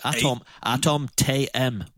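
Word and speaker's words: Atom™: Well, he's from Germany, so I'll pronounce it properly. Atom TM, or TM, you know, trademark.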